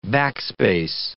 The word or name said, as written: backspace